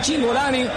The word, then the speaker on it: Cingolani: Si es italiano, debería ser Cingolani, porque la CI se pronuncia como Chi. Ustedes lo saben, ¿no? – Me gustó mucho la pronunciación, como es Cingolani.